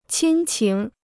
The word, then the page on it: 亲情: qīn qíng